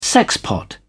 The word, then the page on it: sexpot: British English